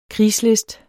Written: krigslist

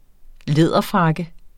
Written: læderfrakke

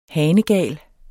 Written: hanegal

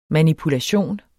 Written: MANIPULATIONEN

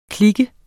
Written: klike

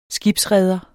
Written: skibsreder

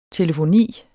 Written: telefoni

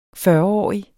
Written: fyrreårig

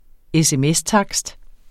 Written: sms-takst

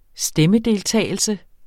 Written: stemmedeltagelse

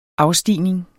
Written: afstigning

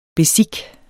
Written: bezique

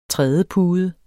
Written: trædepude

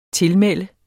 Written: tilmelde

